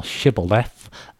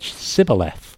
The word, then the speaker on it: Shibboleth: If he said no, then they said to him, "Say now Shibboleth." And he said Sibboleth, for he could not establish to pronounce it right.